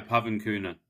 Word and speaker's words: Pavan Kooner: Yeah, Pavan Kooner.